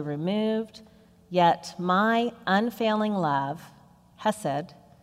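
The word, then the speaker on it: hesed (חסד): The Hebrew word, which means loving kindness, is hesed. It's also sometimes pronounced kesed, but for our purposes, I'll refer to it as hesed today.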